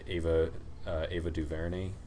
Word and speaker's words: Ava DuVernay: I feel like I always mess up how to say her last name, but Ava DuVernay. Am I saying that right? Do you know who I'm talking about? – No, I'm sorry. – Yeah, I think that's right.